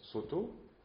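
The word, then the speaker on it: Sōtō: Et puis là, maintenant, on va focaliser davantage sur une forme du Chan, Shaodong, que nous on prononce Soto, puisque c'est comme ça qu'on le connaît sous sa forme japonaise,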